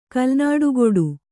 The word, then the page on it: ಕಲ್ನಾಡುಗೊಡು: kalnāḍugoḍu